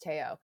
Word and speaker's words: Theo: That would be T-H-E-O, but pronounced Teo.